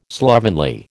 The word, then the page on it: slovenly: American English